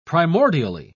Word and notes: primordially: British English

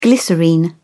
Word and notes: glycerine: British English

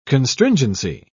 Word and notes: constringency: British English

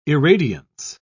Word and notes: irradiance: British English

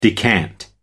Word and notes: decant: American English

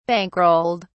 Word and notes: bankrolled: British English

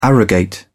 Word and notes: arrogate: British English

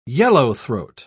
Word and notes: yellowthroat: British English

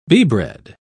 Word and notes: beebread: British English

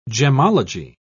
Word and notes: gemmology: British English

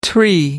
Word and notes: tree: American English

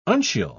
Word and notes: uncial: British English

